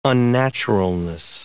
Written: unnaturalness

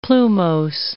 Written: plumose

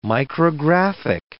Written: micrographic

